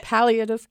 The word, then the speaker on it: palliative: What do you say? – It was perfect. It's palliative care.